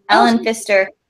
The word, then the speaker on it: Ellen Pfister: And we had the winner for the fortune teller was Ellen Pfister. Or did I say that right? – You totally did. Ellen Pfister.